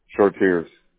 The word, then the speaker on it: Chartiers: How long have you been in business with the, am I saying it correctly, is it Chartiers or Chartiers? – Chartiers.